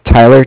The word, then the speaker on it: Tyler: Okay, my name is Tyler, T-Y-L-E-R, and I'm calling with Cardiff. It's spelled C-A-R-D-I-F-F, as in Frank, Frank.